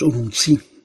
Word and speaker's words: Urumqi: Z Urumqi, bo tak to się wymawia.